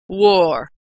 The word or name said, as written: wore